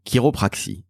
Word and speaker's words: chiropraxie: Alors, chiropraxie, sachez-le, ça s'écrit C-H-I-R-O-P-R-A-X-I-E. Ça se prononce quelque part à l'américaine, chiropraxie. Donc ça n'est pas avec un cas.